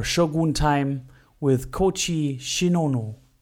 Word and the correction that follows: Shiono: Shiono. Yes, said it right.